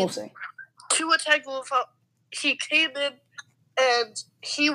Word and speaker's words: Tagovailoa: Like, people now know it's Tua Tagovailoa.